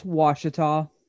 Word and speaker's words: Ouachita: Case in point, the word that I have pulled up on my screen right now is pronounced Ouachita. – Ouachita. – Or as we like to say, oh, you a cheetah.